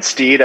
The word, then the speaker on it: Steed: My name is Brian Steed. I've known, my last name is spelled S-T-E-E-D.